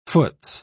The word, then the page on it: foots: British English